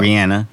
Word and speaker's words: Rihanna: She says her name Rihanna. That's Rihanna. Actually how you say it. According to the artist herself. – Yeah. Okay. So. Rihanna.